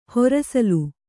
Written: ಹರಸಾಲು

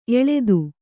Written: ಎಲ್ಲಿತ್ತು

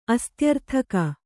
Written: ಅಸ್ತ್ಯರ್ಥಕ

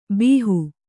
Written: ಭಾಹೊ

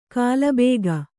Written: ಕಲ್ಬಾಗೆ

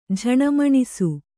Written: ಝಣಮಣಿಸು

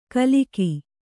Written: ಗಿಲಕಿ

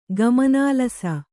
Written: ಗಮನಾಲಸ

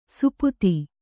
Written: ಸುಪುತಿ

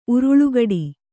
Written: ಉರುಳುಗಡಿ

